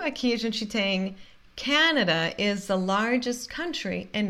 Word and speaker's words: Canada: Canada, não é Canadá, é Canada. Canada is the largest country in North America. E Canada, a primeira sílaba é sílaba tônica, por isso a gente fala corretamente ca, Canada e America.